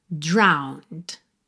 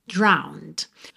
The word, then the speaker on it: drowned: Se escreve como drowned, mas você não pronuncia essa letra E. Então, você vai ter drowned, drowned.